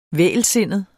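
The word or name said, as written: vægelsindet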